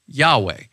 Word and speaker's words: Yahweh: And that name in Hebrew is spelled Yod-Heh-Vav-Heh. And we think it is pronounced Yahweh.